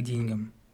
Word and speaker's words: деньгам: К деньгам, правильно говорить.